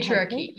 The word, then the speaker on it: patriarchy: but Petrachalia, you can patriarchy, yeah, patriarchy.